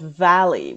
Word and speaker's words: Valle: But this article is about a place called Valley, which is spelled V-A-L-L-E, which is a census designated place, which is essentially it's unincorporated county in Arizona.